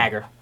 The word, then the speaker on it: Hager: Is that how you pronounce that? – Probably sure. Hager.